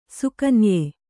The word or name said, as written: ಸುಕನ್ಯೆ